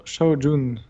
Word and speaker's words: Shao Jun: China, c'est Shao, Shao Jun.